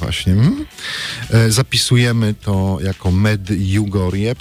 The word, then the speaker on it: Medjugorje: Polska wersja brzmi tak właśnie, Olu, jak wypowiedziałaś, czyli Medjugorje z takim miękkim dżib, tak Medjugorje.